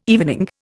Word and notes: evening: Indian

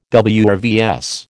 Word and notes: WRVS: American English

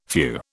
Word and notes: few: British English

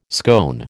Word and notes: scone: American English

